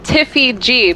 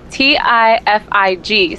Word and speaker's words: Tifig: It was it was pronounced Tiffy G, but it was spelled T-I-F-I-G. So people would call me Tiffig or Ti-Fi G. Like, they didn't know how to pronounce it.